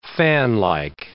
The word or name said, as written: fanlike